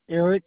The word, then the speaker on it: Eric: Eric, E-R-I-C. – E-R-I-C, last name? – Benson, B as in boy, E-N-S-O-N.